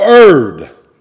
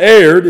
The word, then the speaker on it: err: Who concerning the truth have erred. And when you see E-R-R, that's err. Look at the dictionary. You see E-R-R-O-R, that's error. This is not erred, it's err. That's the proper pronunciation of it.